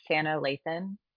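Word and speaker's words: Sanaa Lathan: Well, first and foremost, I think her name is pronounced Sanna Lathan, and she played Blade's mother.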